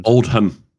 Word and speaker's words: Oldham: Oldham. Oldham. – Oldham. – Oldham. – Oldham. – Oldham. Oldham, as we pronounce it.